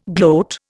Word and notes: gloat: Indian

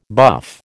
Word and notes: buff: American English

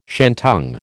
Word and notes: shantung: American English

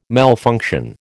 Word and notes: malfunction: American English

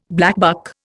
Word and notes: blackbuck: Indian

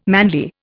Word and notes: manly: Indian

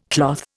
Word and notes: cloth: Indian

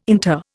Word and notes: inter: Indian

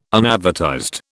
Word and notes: unadvertised: British English

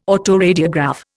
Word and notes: autoradiograph: Indian

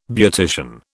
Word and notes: beautician: British English

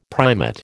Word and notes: primate: American English

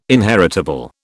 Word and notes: inheritable: British English

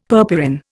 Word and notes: purpurin: Indian

